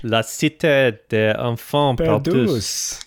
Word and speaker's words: La Cité des Enfants Perdus: La Cité des Enfants Perdus.